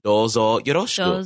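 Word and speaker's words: Dozo yoroshiku (どうぞよろしく): Watashi wa Mike Oriana des, and then the last part is just dozo yoroshiku. – Dozo. Like, dozo, nice, how do you say it? Dozo oroshiku? – Dozo yoroshiku. So Y-O-R-O-S-H-I-K-U.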